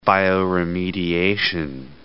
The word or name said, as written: bioremediation